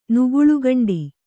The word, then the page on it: ನುಗುಳುಗಂಡಿ: Kannada